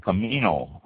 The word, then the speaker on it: Comino: And then there's two other smaller islands that are off the northern tip of it, Gozo and Comino, I believe that's how it's pronounced. Gozo, G-O-Z-O, so it makes sense to be Gozo. And Comino, C-O-M-I-N-O.